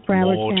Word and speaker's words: Broward: Michael Burns of Broward, am I saying that right? – Broward County.